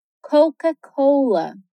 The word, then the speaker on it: Coca-Cola: A gente pronuncia como Coca-Cola. Coca. Cola. Coca-Cola.